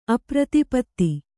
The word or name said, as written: ಅಪ್ರತಿಪತ್ತಿ